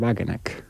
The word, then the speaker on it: Wagenknecht: es el de Sarah Wagenknecht, o como claro. se diga en alemán, ¿no? – Está bien pronunciado, ¿eh? – Sí. Bueno, pensé que me ha salido demasiado sajón, pero vale.